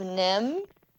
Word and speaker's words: NIMH: I have one, and it is Miss Frisbee and the Rats of Nim.